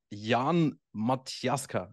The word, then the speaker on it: Jan Matiaska: So Jan Matiaska, am I saying this correctly? – Perfectly.